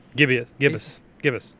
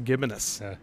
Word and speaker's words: gibbous: I always thought it was pronounced gibbonous, but that's pronounced, I am from a rural Hickwater where we add extra syllables in words. – You have the wrong emphasis on the wrong syllable.